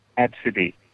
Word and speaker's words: Abcde: It's spelled A-B-C-D-E, and it's pronounced Absidy.